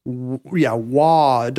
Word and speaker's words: Wauconda: spelled D-W-A-U-C-O-N-D-A. I didn't even put this together, but did you know there is a Wakanda, Washington, spelled the same way? – Wakanda. – Wauconda.